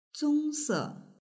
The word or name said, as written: zongse